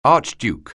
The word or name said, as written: archduke